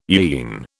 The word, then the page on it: yean: British English